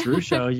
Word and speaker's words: Ruscio: It's Ruscio.